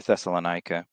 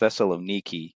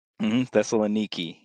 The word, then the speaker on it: Thessaloniki: The overall idea and who's writing it, you know, Paul's writing it to the believers in Thessalonia or Thessalonica, which is actually pronounced Thessaloniki, you know, but us Americans, we don't seem to care. – That's the Greek, isn't it? – Thessaloniki. Yep. Yeah.